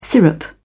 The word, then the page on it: syrup: British English